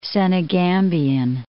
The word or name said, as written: Senegambian